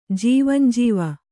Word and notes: ಜೀವಂಜೀವ: jīvanjīva

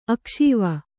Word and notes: ಅಕ್ಷೀವ: akṣīva

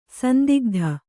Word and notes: ಸಂದಿಗ್ಧ: sandigdha

